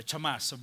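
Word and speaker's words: Hamas (חמס): The Hebrew word for violence is Hamas. Interesting. Or Chamas. But Hamas, how they pronounce it.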